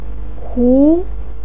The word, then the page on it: hu: 2